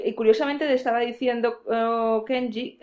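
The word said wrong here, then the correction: Jenji: eh, Genji, o... es Genji. Genji, perdón, Genji Kohan, eh, que...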